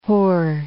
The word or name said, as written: Hoare